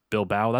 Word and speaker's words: Bilbao: Is that Bilbao? How do you pronounce that? – Bilbao. – That's what I figured. But I'm glad it's not Bilbo,